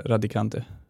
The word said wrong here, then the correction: Ravikant: Ja. Ravikant. Ravikant, ja det känns, ah, inte radikant, radikal.